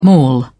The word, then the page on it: mall: British English